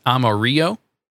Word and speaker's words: Amarillo: I wonder, you know, given this logo, it's almost as if they're trying to say, don't pronounce the two L's, so it's Amarillo.